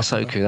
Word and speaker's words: Ahsoka: Yeah, is it Anakin and Ahsoka in the Star? – Ahsoka, that's it.